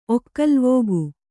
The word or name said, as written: ಒಕ್ಕಲುವೋಗು